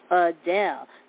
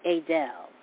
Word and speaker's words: Adele: The correct pronunciation is Adele, not Adele, according to the star, who then moved on to answer the woman's question.